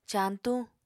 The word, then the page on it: cañtuñ: apatani